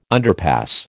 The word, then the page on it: underpass: American English